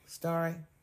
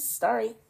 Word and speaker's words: Starry: Siri? – Starry. – Lemon and lime mix. Oh, did I say the wrong word? – No. You said it right. It was Starry.